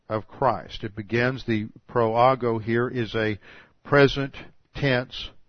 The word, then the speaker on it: proago: The verb translated transgresses is the Greek proago.